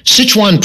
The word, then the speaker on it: Sichuan: You perhaps can help us here, with the pronunciation of S-I-C-H-U-A-N. – Sichuan. – That's the way I pronounced it, and I thought it sounded more like Sichuan, like the Chinese restaurant up the street, you know, Sichuan Village. Sichuan. S-I-C-H-U-A-N.